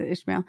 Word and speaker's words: Ishmael: It is Ishmael.